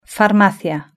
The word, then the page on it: farmacia: Spain Spanish